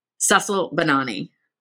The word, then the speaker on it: Sussle Bonanni: Sussle Bonanni.